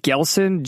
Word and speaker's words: GLSEN: So all of this is related to this nonprofit called, I don't know how you pronounce it, Glesen, G-L-S-E-N. How do you pronounce that? – I don't know. That sounds about right.